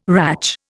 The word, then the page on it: ratch: Indian